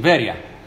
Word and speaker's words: Veria: Well, it's a terrible pronunciation, Veria.